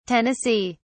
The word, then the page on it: tn: British English